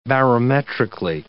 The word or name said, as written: barometrically